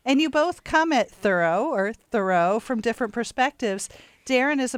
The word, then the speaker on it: Thoreau: His name is Thoreau, rhymes with furrow, as in the furrow in a garden.